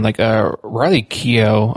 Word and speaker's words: Keough: But then Riley Keough, I think that's how you say her name. – Yeah.